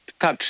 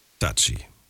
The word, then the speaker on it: Touchy: Also äh, zum Thema äh, Touch, ja? – Touchy.